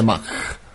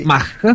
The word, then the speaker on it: Mach: Probabilmente Mach. – Sì. Mach. Dal nome del fisico tedesco Ernst Mach.